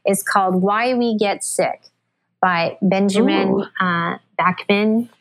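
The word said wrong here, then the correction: Bikman: How do you say his name? Bickman? Benjamin Bickman.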